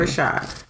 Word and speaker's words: Rashad: Rashad.